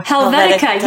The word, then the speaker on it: Helvetica: Did I say it right? Helvetica? Helvetica, yes.